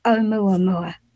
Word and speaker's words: Oumuamua: So let's get on to Oumuamua because okay.